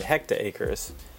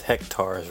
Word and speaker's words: hectares: And I called it hecta acres. And my coworker looks at me and he goes, you know it's pronounced hectares, right? – I was waiting for that.